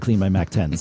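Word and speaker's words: CleanMyMac X: They were a sponsor for a short period of time, and they corrected us when we did the read and said Clean My Mac 10. So Clean My Mac X is definitely the right way to say the name of that app.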